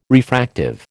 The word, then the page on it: refractive: American English